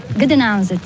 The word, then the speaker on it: Gdańsk: Gdańsk.